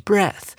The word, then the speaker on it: breath: 或者是吸的动作，或是你的气息，我们要念成 breath。注意到这个 e a 呢，念成了 a，也就是 head h e a d，跟那个头部一样的发音。